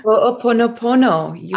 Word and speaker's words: Ho'oponopono: It's Hawaiian, and I'm going to butcher the name. Ho'oponopono. – Ho'oponopono. – I was really close.